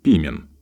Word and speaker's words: пимен: Греческий прототип слова «пастырь» стал именем собственным и в русском языке употребляется с ударением на первом слоге – «пимен».